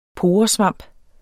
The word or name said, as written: poresvamp